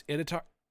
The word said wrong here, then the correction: Iditarod: Iditarod. Iditarod. No, yeah, I did, Rod.